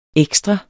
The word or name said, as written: ekstra